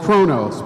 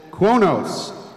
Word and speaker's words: Qo'noS: The Klingon homeworld in Federation Standard is called Kronos, but if you ask your average Klingon, they call it Kuonos. Can you spell it? – Kronos. Q-O-N-O-S.